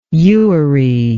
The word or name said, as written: ewery